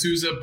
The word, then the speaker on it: Souza: It's Souza, but I think you were pretty darn close to that.